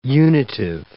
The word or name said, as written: unitive